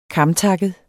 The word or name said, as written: KAMGARNET